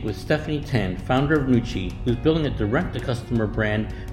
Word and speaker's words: Nooci: Nooci in Chinese is pronounced Nooci, which means women's energy.